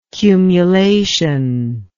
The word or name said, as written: cumulation